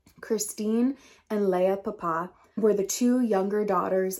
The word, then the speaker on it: Papin: This is the case of the Papin sisters. Now, it is spelled P-A-P-I-N, but in French, it is pronounced Papin. I'm probably pronouncing it wrong, but I did look it up and it's like, it's Papa.